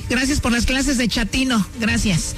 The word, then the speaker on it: chatino: Chapino. – ¿Chapino? – No, chatino. – Es como chato, ¿verdad? Chatino.